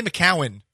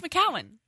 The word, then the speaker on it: McCown: You say McCowan. – Josh McCown. – It's McCown. – Josh McCown. – Okay. So this must be an Indiana thing. – Josh McCown. – There's like a third syllable that you're adding to it.